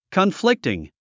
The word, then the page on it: conflicting: kənflíktiŋ; コンフリクティン